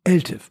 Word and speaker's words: ELTIF: Also es geht um ELTIF, European Long-Term Investment Fund. Habe ich das richtig gesagt? Hallo, liebe Kollegen. – Hallo, hoher Respekt dafür. – Hallo, perfekt.